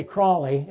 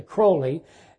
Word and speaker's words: Crowley: Jimmy Page, Robert Plant, they praised the teaching of Crowley, and I always get his name wrong. I want to say Crowley. It's actually Crowley.